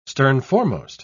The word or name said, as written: sternforemost